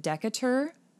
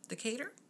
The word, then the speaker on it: Decatur: So Chapman was born May 10th of 1955 in Fort Worth, Texas, but he was raised a majority of his life in, I believe you pronounce it Decatur, Georgia? – Decatur? – Decatur. Decatur. Probably Decatur. Decatur.